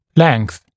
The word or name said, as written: length